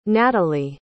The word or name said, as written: natalie